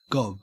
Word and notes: gob: Australian English

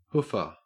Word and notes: hoofer: Australian English

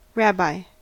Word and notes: rabbi: American English

